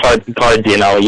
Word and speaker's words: Cardinali: Cardinali. – Cardinali. Could you spell it for me? – Cardinali.